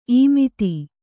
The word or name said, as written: ಇಮ್ಮತ್ತಿ